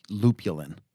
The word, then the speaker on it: lupulin: I do too, and we've been saying it for so long, but I knew that it was lupulin, but it just sounds weird now. But that is how it is pronounced.